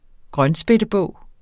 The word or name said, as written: grønspættebog